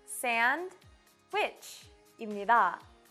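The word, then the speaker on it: sandwich: 샌드위치의 정확한 발음은 sandwich입니다. 빨리 말하면 sandwich가 되고요.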